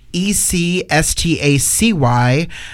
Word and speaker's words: Ecstacyinferno: Maybe I'd quickly like to say, since this is radio, Ecstasy, you spell your name with a C, right? – I do. It's spelled Ecstacyinferno.